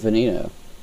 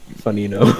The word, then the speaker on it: Veneno: Veneno. – It's V-E-N-E-N-O. – Yeah, oh, Veneno. I always pronounced it Veneno. – Ah. – It's like Venom. Venom.